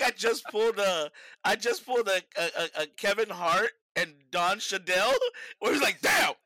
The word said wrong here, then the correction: Don Cheadle: That was a Don Cheadle, isn't it? Don Cheadle.